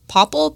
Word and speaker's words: Popl: The one I was looking at was, I believe it's pronounced Popple, P-O-P-L.